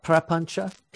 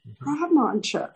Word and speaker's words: prapancha: Oh, Prapancha, Prapancha. And the C is pronounced CH. – Oh, okay. Prapancha.